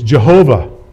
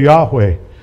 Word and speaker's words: Yahweh: The old pronunciation is Jehovah. Some of the older works, if you read the older people, the older saints that have gone before us, they will have Jehovah as opposed to Yahweh. But modern study has determined that this is more of a correct way to say it.